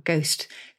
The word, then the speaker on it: ghost: And so they lobbed an H into our words, and that's how we've got the silent H in our ghost, ghastly and aghast, because there was a kind of ricochet effect.